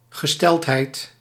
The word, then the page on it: condition: /kən.ˈdɪ.ʃən/; American English; [kən.ˈdɪ.ʃən]